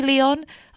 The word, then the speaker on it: Lheon: This artist is called Leon. It's actually Leon. I don't know why I said it like that. It's spelled L-H-E-O-N.